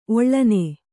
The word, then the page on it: ಒಳ್ಳೆಣ್ಣೆ: oḷḷeṇṇe